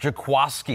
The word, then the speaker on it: Jaquiski: Who there's been some controversy because it's spelled like Jaquiski. – I know. I've said Jaquiski for the first 2 years in his league, but it's Jaquiski.